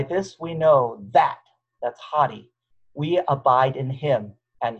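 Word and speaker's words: hadi (ὅτι): It's pronounced hadi, okay? It looks like adi, but this little backwards comma right here gives it the H sound, so it's actually pronounced hadi,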